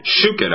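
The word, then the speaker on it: Schuchat: Shuchat, I believe is the way it's pronounced, yeah.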